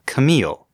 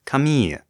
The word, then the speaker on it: Camille: After last week's episode, Linny, one of our listeners, expressed some surprise that we were pronouncing that one character's name, Camille, the way it's said in English, where it is used almost exclusively as a woman's name, instead of the French name Camille, which is spelled identically, but is given to both men and women.